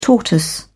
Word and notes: tortoise: British English

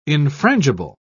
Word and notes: infrangible: British English

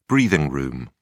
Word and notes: breathing room: British English

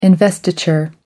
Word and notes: investiture: American English

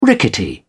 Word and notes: rickety: British English

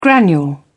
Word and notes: granule: British English

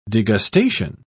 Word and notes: degustation: British English

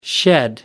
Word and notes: shed: American English

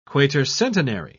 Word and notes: quatercentenary: British English

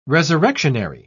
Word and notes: resurrectionary: British English

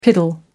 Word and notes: piddle: American English

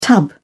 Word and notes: tub: British English

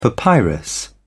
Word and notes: papyrus: British English

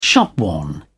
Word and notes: shopworn: British English

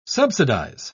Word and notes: subsidise: British English

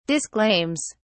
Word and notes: disclaims: British English

